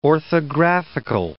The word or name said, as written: orthographical